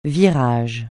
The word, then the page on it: virage: francais